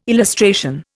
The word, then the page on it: illustration: Indian